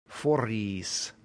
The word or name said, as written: foris